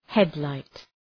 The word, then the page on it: headlight: ‘hed,laıt